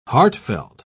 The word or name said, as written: heartfelt